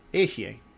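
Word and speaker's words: Ehye (אהיה): The next one that gets said is Ehye.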